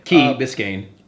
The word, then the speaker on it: Key Biscayne: So they filmed this in Cape Florida State Park, which is in Key Biscayne. Am I saying that right? – Yeah. Key Biscayne.